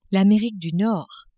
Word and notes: Amerique du Nord: French

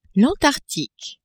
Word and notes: Antarctique: French

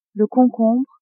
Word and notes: concombre: female

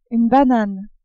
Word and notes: banane: French

